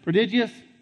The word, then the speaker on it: prodigious: I can't pronounce the next word. What does that say? Prodigious? Thank you.